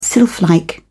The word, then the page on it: sylphlike: British English